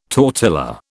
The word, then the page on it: tortilla: British English